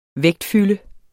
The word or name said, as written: vægtfylde